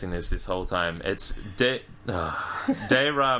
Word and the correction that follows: De Re: Militari. De, de Re.